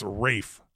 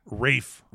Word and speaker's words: Ralph: I think it's pronounced like, and it's weird. I think it's Rafe. – Rafe? – Rafe. – Rafe? – Spelt Ralph, I think.